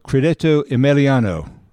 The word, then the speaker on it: Credito Emiliano: So, a bank in Italy called Credito Emiliano.